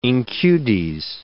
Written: incudes

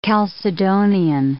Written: Chalcedonian

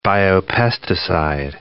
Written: biopesticide

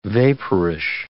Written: vaporish

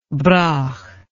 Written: Brough

